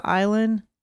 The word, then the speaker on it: Islay: Head to the island of...